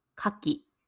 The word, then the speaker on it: 牡蠣: ほんの少しだけ発音が違うのわかりますかオイスターは牡蠣。そして、果物は柿です。ただ、地域によっては発音も少し違うかもしれません。